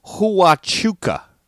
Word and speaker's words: Huachuca: What McDonald's innovation was first rolled out at Fort Hachura near Sierra Vista, Arizona, 1975? I know I'm butchering that name. Fort Huachuca near Sierra Vista, Arizona, in 1975.